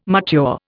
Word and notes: mature: Indian

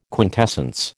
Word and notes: quintessence: American English

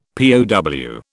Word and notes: POW: British English

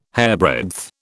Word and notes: hairbreadth: British English

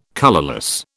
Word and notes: colourless: British English

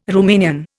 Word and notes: Rumanian: Indian

